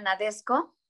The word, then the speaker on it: Nadesico: hay otra película también que se llama Nadesco. Nadesco o bueno, se escribe Nadesico, pero mucha gente le dice Nadesco.